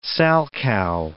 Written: salchow

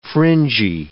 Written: fringy

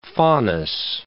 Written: Faunus

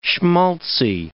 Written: schmaltzy

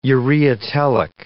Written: ureotelic

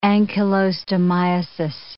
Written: ancylostomiasis

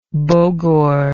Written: bogor

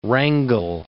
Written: Wrangell

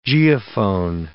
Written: geophone

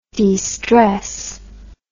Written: destressing

